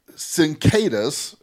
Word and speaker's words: cicadas: So what they're saying are these cicadas, cicadas, thank you, I'm saying it wrong.